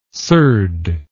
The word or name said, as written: surd